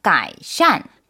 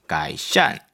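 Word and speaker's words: 가이샨 (改善): 굉장히 발음이 비슷해요. 네. 2번 가이샨. – 가이샨.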